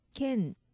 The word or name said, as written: ken